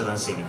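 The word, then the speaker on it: Dunsany: Intanto Lord Danzani, la pronuncia sembra sia questa, insomma, almeno è il suo pronipote attuale, il 21esimo barone Danzani, pronuncia Danzani, quindi chi siamo noi per dire che non è così.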